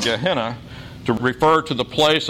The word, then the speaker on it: Gehenna: The term that James uses for hell is Gehenna, sometimes pronounced Gehenna in English.